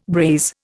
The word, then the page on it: braise: Indian